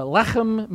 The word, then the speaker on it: lechem: Uh, lechem means uh, L E C H E M. You have to say it like that. You want to say it with you? You should say it. Try it with me. Lechem, lechem.